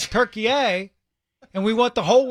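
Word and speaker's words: Türkiye: World Cup USA men playing Turkey A at 6. That's how you say it. I'm telling you. It's not just Turkey. It's Turkey A.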